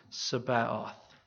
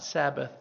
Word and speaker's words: Sabaoth: This name in Hebrew, if I'm saying it properly, is Jehovah Sabaoth. Not Sabbath, but Sabaoth.